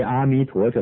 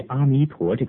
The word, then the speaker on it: 阿弥陀: 因为阿弥陀这三个字的意思无法用少数几个中文意思表达出来，于是就照梵文名号译音，根据巴利文的发音或根据后期的梵文发音，对于阿弥陀这个陀字就念成达字。